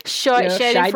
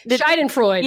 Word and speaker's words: Schadenfreude: Schadenfreude. – Whatever the fuck it is. – Yeah. – Schadenfreude.